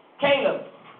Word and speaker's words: Caleb: What I love about Cable. Am I saying his name right? Cable. Caleb.